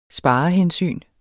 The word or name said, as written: sparehensyn